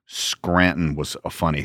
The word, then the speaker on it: Scranton: And if you're from here, you don't pronounce the T. – Right, Scranton. – Scranton. – Scranton.